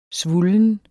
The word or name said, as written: svullen